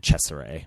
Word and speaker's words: Cesare: Well, when I look it up, because it's spelled C-E-S-A-R-E. And then later in the film, once it's revealed that the doctor's inspired by this Caligari from Italy, I have to assume that it's Cesare.